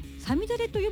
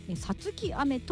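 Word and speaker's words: 五月雨: 5 月雨と書いてサミダレと読むんですけども、あの一般的にはね、ただこの曲はサツキ雨と読むそうです。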